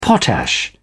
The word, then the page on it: potash: British English